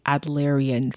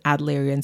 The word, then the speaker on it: Adlerian: Hi, my friends, popping in here for a second to say that the pronunciation is Adlerian psychology, okay? It's Adler and Adlerian psychology. I do not know why I have always struggled with this for years, but I always say it incorrectly. So if you hear me mispronounce it, I apologize, but that is the correct pronunciation. It's Adlerian and Adler.